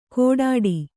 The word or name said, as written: ಗೊಡ್ಡಿ